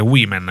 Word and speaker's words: women: Ok, nella giornata di oggi, appunto 8 marzo, uscirà nelle sale italiane Woman Talking, un film diretto da Sarah Paul. – Hai sbagliato per esempio la pronuncia, perché women al plurale, eh? – Women. – Ha detto woman.